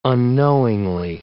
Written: unknowingly

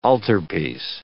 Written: altarpiece

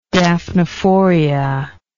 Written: daphnephoria

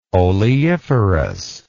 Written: oleiferous

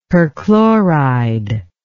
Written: perchloride

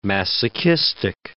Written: masochistic

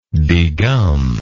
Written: degummer

